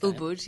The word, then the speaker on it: Ubud: Am I saying that right? Ubud? – I think Ubud, yes. Ubud.